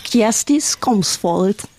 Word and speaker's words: Kjersti Skomsvold: Und du hast den Namen vorhin so toll ausgesprochen. Kannst du bitte nochmal? – Kjersti Skomsvold. – Toll, das klingt sehr norwegisch, wenn du das so sagst.